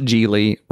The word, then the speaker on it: Gigli: It is pronounced Gigli, rhyming with really.